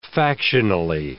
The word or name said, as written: factionally